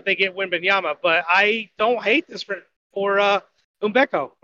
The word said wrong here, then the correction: Ime Udoka: or Unubeko. I don't even know how to pronounce his name. – Ime Udoka. – Ime Udoka.